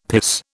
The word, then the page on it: piss: British English